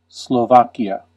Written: Slovakia

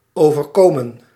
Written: happen

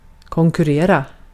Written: yarışmak

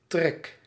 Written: draught

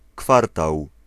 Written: quarter

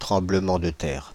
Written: tremblement de terre

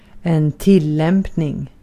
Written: tillämpning

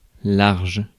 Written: commodious

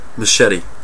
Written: satır

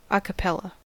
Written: a cappella

